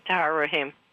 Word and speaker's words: Tahar Rahim: Is it Tahar Rahim for Madam Web? – Tahar Rahim.